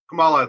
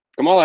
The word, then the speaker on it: Kamala: But it's sponsored by presidential candidate Camilla. No. – Yeah. – Harris? How do you pronounce Harris's first name? – Kamala, I think it is. – Kamala Harris. All right.